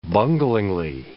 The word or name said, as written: bunglingly